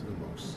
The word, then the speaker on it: Lumos: Lumos. Is that, am I pronouncing this right? – Yeah, you did those two right.